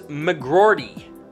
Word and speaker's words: McGroarty: Rutger McGroarty. – Rutger? – Rutger McGroarty. – Can you pronounce the last name? – I will. I've done extensive research. The last name is McGroarty.